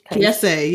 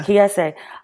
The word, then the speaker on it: Kiese: Kiese, yeah. – Kiese.